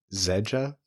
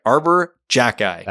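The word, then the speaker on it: Arber Xhekaj: His last name is spelled X H E J A H. – Zedja? – Arbor Jacki. – That's. – Yeah, there's video of him saying it. That's correct. Arbor Jacki.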